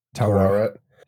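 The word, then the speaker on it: Taweret: Other than Tawit. Is that how you say it? Tawret. Yeah.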